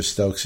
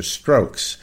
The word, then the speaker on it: Stokes: 498, Jonathan's famous mispronunciation of Professor Stokes is Professor Strokes.